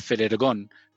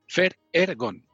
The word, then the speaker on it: Ferhergón: no, hombre, eso, es muy fácil, porque como a estas alturas todo el mundo sabe, son las iniciales de mi nombre y mis dos, dos apellidos. Entonces, como son sílabas sucesivas, pues las pronuncias como en español. Entonces, Fernando, Er, de Herrera, Gon, González, Ferergón. Fer, Ergon.